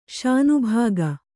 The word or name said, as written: ಶಾನಭಾಗ